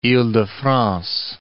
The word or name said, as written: ile-de-France